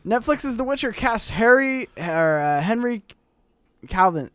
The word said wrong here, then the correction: Cavill: How do you say his last name? Cavill. That's it. Henry Cavill as Geralt of the Riviera for the Witcher Netflix series.